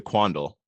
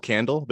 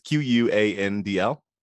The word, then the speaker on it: Quandl: One was about NASDAQ Datalink, which is a service previously known as, I'm going to pronounce it Quandl. I always want it to be called Candle, but it's Quandl.